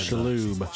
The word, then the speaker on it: Shalhoub: Shalhoub. – Shalhoub will be voicing Splinter, and that he was Monk.